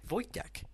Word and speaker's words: Vojtěch: Vojtěch.